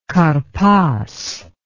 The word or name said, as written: karpas